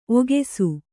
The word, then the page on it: ಒಗಸು: Kannada